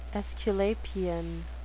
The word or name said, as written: aesculapian